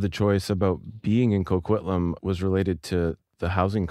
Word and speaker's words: Coquitlam: You're in Coquitlam? – Yeah. Coquitlam, that's right. You got it.